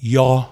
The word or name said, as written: Yawhaw